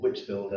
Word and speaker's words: Whitefield: And George Whitefield, or Whitfield as it's sometimes pronounced,